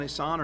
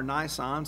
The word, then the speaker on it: Nisan: So every year thereafter, on the 14th of Abib, also called Nisan or Nisan, some pronounce it, God commanded that a lamb, the Passover lamb, be slain for every Hebrew household according to his instructions.